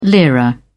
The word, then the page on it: lira: British English